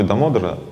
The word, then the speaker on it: Дамодара: В слове «дамодара» — «дама» — «удара», ударение на «о» — «дамодара».